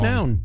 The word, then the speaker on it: McNown: McNown. You know? – I always say, because it's M-C-N-O-W-N. McNown. – Yes. So that is how I pronounce it and have pronounced it for my whole life.